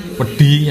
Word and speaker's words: wedhi: Terus kalau dituliskan ke dalam ejaan yang istilahnya kalau sama seperti di Indonesia, ejaan yang disempurnakan itu kalau D yang palatal itu dan T itu pakai H. – Ada D. – Ya, jadi misalkan tadi pedi yang artinya pasir itu menuliskannya itu WEDHI.